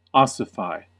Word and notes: ossify: American English

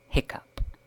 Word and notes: hiccough: British English

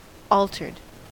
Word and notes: altered: American English

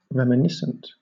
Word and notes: reminiscent: British English

